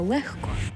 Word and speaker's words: легко: Пишемо «легко», але вимовляємо «легко».